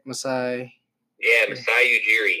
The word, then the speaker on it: Masai Ujiri: Masai Ujiri, did I say that right? – Masai Ujiri.